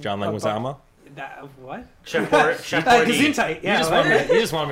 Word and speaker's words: Leguizamo: Isn't Leguizamo.